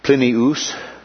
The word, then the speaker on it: Plinius: He was a friend of Plinius, as we pronounce it there, Secundus, the governor of Bithynia.